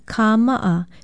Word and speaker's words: kāma'a: Aloha, I'm Leilani Poliahu of Hawaii Public Radio, and today's Hawaiian word is kāma'a, for shoe, sandal, slipper, boot, tea leaf, or tapa sandal, shoes. Be sure to spell it with a stress mark, a kahako over the first A, and an okina between the last two A's.